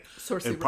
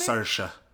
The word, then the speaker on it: Saoirse: Saoirse Ronan? – Yeah, in part. – Is that how we say her name? – Saoirse.